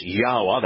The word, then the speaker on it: Yahweh: In other words, Yahweh, Yod-Heh-Wah-Heh, is always Yahweh. That's how it's pronounced no matter what language you're using.